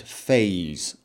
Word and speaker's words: F4SE: For example, the script extender, which will almost certainly be called Fallout 4 Script Extender or F4SE, pronounced Phase, I believe. I believe that is what the team have decided they want to call it Phase.